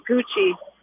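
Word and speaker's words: Gouchie: I changed it when I was like 14 years old, and it stuck, K-Y-M, G-O-U-C-H-I-E. So it phonetically, it would look like Gucci, but it's actually Gucci.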